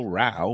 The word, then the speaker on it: Rowe: It's spelled row, like row your boat, R O W E, but pronounced Rao, as in the British word for fighting.